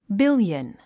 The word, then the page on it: billion: bíljən; ビュリエン